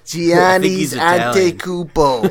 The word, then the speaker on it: Giannis Antetokounmpo: So you just mispronounced his first and last name. – You know that Giannis guy? – Giannis Antetokounmpo.